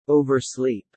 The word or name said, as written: oversleep